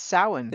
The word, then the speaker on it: Samhain: I will have enjoyed our Samhain, and it's not spelled like you think. It's because it's a Celtic word, and it's Samhain, and it's pronounced Samhain.